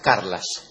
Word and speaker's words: Carles: He sabido que mañana voy a hacer una nota radial, mañana o pasado, creo, en donde su amigo Carles, o Carla, como usted quiera llamarlo... – Se escribe Carles, más se pronuncia Carlas.